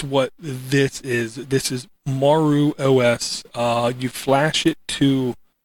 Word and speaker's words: Maru: And I'm going to say this is pronounced Maru. M-A-R-U.